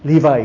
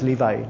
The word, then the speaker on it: Levi: But Levi is Levi, not Levi. Only in Malay and Indonesian, they pronounce as Levi and Naphtali. But in English, it's Naphtali and Levi.